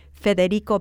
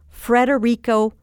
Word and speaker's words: Federico: And one day I remember being yelling at my radio because they were talking about the former Secretary of Transportation, Federico Peña, but they pronounced his name Frederico Piña. – Hmm. – Well, a piña is a pineapple, and there's only one R in Federico. It's not Frederico.